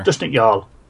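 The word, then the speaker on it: Dushnikh Yal: Dushnikyal. – Yeah, Dushnikyal. What was that? You said it right earlier. – Dushnikyal.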